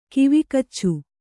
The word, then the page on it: ಕಿವಿಗಚ್ಚು: kivigaccu